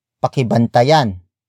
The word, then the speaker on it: pakibantayan: Word for word it's, pakibantayan, ninyo, ang aking bahay pakibantayan ninyo ang aking bahay pakibantayan ninyo ang aking bahay The first word is pakibantayan pakibantayan pakibantayan.